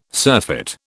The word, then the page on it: surfeit: British English